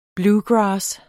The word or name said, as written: bluegrass